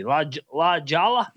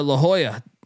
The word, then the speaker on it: La Jolla: How do you pronounce that fucking city? La Jolla? – No, La Jolla. La Jolla, man.